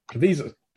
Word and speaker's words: Caviezel: Caviezel.